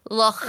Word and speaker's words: Loch: Because there was a 400-year-old shark in Greenland, But then Connor's come in and gone, Sabrina, it's pronounced Loch. – Wait, is this guy trying to pronounce something in text? – Yeah, so it was L-O-C-C-C-H-H dot dot dot Loch. – Right, because it's Scottish pronunciation.